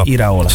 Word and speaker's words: Iraola: Iraola.